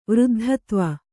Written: ವೃದ್ಧತ್ವ

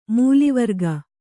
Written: ಮೂಲಿವರ್ಗ

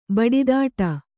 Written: ಬಡಿದಾಟ

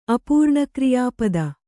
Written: ಅಪೂರ್ಣಕ್ರಿಯಾಪದ

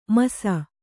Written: ಮುಸಿ

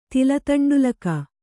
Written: ತಿಲತಂಡುಲಕ

